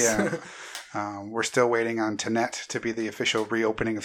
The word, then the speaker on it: Tenet: Yeah, it's Tenet. – There's no N in there. It's T-E-N-E-T.